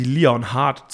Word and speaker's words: Leonhardt: Und die würden im Englischen einen Leonhard ohne H in der Mitte Lennart aussprechen. Wir wollten nicht Lennart Korn sein. Und äh, deswegen haben wir, zwingen wir den Engländer quasi Leon Hart zu sagen, auch wenn das Hart eigentlich E-A-R-T geschrieben wird.